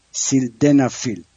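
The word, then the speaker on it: sildenafil: T-A-L-A تدالفیل. البته فراموش نکنم اسم اون ویاگرام هست سیلدنافیل، S-I-L سیلدنافیل، که دوستان بودن که چطور اینو تلفظ کنم.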